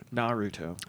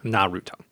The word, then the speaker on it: Naruto: Firstly, Naruto and pretty much all three-syllable Japanese names are pronounced with stress on the first syllable only. – Naruto. – Naruto.